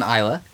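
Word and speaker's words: Islay: On Isla. – Well done. You're learning the pronunciation of island now. You've completely learned how to pronounce an island.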